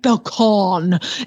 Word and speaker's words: pecan: And why is it pecan? It's not a pecan. It's a pecan. Like, why would you say, I mean, because clearly you wanted to say pecan when you heard it spelled. That's how you say it. It's not a pecan. I'll have some pecan pie. I like pecans. It's pecan pie.